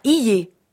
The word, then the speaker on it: hier: H-I-E-R. – Je ne prononce pas hier comme dans cahier, mais hier.